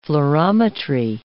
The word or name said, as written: fluorometry